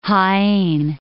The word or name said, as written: Jaen